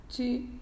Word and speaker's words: tu: chi, pronunciado chi, porque si pronunciamos tú, ese sonido U español, ellos lo van a escribir como una O y una U. Si queremos que escriban una T y una U, tienen que pronunciar chi.